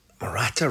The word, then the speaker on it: Morata: Morata rhymes with erratic, or at least it's onomatopoeic.